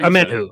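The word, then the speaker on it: Omenihu: Amenahue. Charles Amenahue. Oh, Amenhu, yeah, Amenhu.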